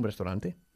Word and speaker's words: restaurant: Vamos a ver ahora ocho palabras que tienen que ver con un restaurante. ¿Cómo se dice restaurante en inglés? – Restaurant. – Eso es, restaurant, con solo dos sílabas. Muy bien. No decimos restaurant, es restaurant.